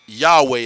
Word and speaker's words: Yahweh: Yahweh is the way we pronounce it.